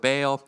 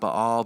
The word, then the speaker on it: Baal: the prophets of Baal. I think it's pronounced Baal, but if I say that, people look at me weird.